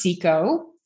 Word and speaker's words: Ciecko: My last name is pronounced Seco.